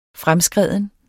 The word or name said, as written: fremskreden